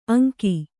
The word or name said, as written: ಅಂಕಿ